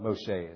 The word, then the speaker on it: Moshe: That Moshe is the Hebrew pronunciation for Moses.